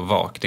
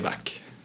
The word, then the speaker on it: vak: Alltså uttalas det så. Vak, det är inte vack. – Nej, det är vack.